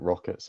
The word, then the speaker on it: ROKiT: Yeah, it was ROKiT Venturi, and again, everyone there called it ROKiT, so I think we understand how it's supposed to be pronounced at this point in time.